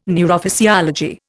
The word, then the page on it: neurophysiology: Indian